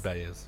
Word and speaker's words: Baez: Joan um, Baez. Uh, I said that wrong again. Baez, um, yeah. Bayes? Bayes.